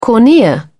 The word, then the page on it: cornea: British English